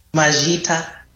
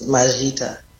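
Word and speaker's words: Maita: And how do you say thank you? – Majita. That's Majita.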